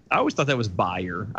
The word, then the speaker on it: Beyer: Bayer. – Bayer, uh, spelled like the company Bayer. – Oh, the B A E R or whatever that is. Mm, yeah, B E Y E R.